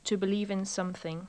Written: to believe in sth

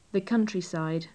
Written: the countryside

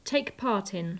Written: take part in

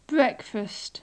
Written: breakfast

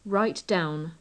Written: write down

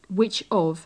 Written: which of

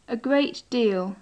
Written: a great deal

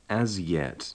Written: as yet

